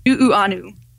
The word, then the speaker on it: Nuuanu: And was that the Jackass Ginger Pool in Nuanua? – Nuuanu.